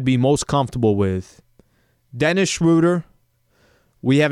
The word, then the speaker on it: Schroeder: By the way, it's Schroeder. Everyone's going to pronounce his name. I think I've heard 9 different pronunciations in the past week and a half. Schroeder.